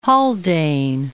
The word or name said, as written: Haldane